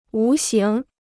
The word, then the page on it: 无形: wú xíng